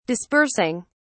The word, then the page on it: dispersing: British English